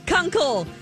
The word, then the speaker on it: Kunkel: Kunkel. – K-U-N-K-E-L.